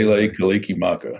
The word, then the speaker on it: Mele Kalikimaka: Then, Mel, I always say this wrong. I got to sing it to say it right. I just can't talk it, but Mele Kalikimaka Maka is Hawaii. – Mele Kalikimaka. – There you go.